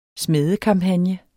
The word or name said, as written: smædekampagne